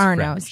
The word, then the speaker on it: Arnaud's: But if you have a chance to go to the Sunday Jazz Brunch at Arno's, it is fantastic. – And Arno's is spelled A-U-D. So it's... looks like Arnaud's. – Right. – But it's Arnaud's.